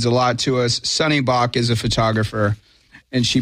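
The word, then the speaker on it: Sunny Bak: Sunny Bach.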